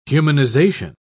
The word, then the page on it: humanization: British English